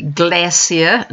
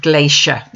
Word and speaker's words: glacier: Note that the New Zealand pronunciation is with an S, not a SH. So we say glacier, not glacier.